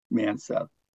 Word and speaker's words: Manseth: And also my name is, you can search under my name, Ron Manseth, M-A-N-S-E-T-H.